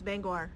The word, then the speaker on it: Bangor: Bangor. No, it's Bangor.